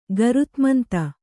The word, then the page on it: ಗರುತ್ಮಂತ: garutmanta